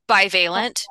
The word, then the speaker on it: bivalent: And if the panel votes in favor of this, then Pfizer and Moderna's is it pronounced bivalent or bivalent? – Bivalent.